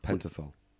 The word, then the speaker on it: Pentothal: Two psychiatrists gave Hirons sodium pentothal. – Pentothal.